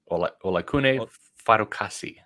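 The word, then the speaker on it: Olakunle Fatukasi: Ola Kuple Fadukowski. No, that's not it. Fadukasi. – Olakune Fadukasi. – Olakune Fadukasi. Yeah.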